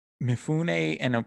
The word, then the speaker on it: Mifune: Mifune I'm sorry if I'm butchering the pronunciation. – That was good. You got it right.